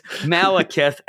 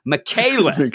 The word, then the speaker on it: Malekith: We know it's Malekith and not Michaelith.